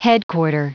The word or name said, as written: headquarter